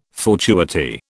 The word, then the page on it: fortuity: British English